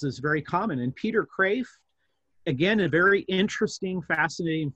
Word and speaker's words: Kreeft: Peter Kraft was, it's a Dutch name, so even though it sounds like Kreeft, it's pronounced Kraft.